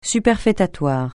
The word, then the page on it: superfétatoire: francais